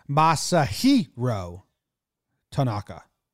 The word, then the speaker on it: Masahiro Tanaka: According to Baseball Reference, you pronounce it Masahiro Tanaka.